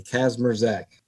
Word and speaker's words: Kazmierczak: Leave the I and the C out. So spell it K-A-Z-M-E-R-Z-A-K. Kazmierczak.